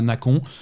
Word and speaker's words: Nacon: Et voilà, et je pense, et c'est parce qu'ils sont français que je choisis de prononcer ça Nacon. Mais ça, c'est voilà, ça s'écrit Nacon, ça se prononce Nacon.